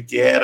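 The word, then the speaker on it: Kjær: nebo vlastně bez toho Ječka by se to mělo ofi- oficiálně vyslovit, takže Kier, uh, Schmeichel, Herberg, Heberg ve středu hřiště.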